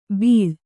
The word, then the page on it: ಬೀಳ್: bīḷ